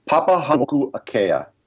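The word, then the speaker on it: Papahānaumokuākea: Papahanuku'akea, which is basically the outer Hawaiian Islands.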